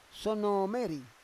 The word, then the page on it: sono me ri: Roon